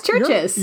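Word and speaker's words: Chvrches: It's churches. It's spelled like with a CV. – V. Yeah, so I always like to call them churches because that's how it's spelled.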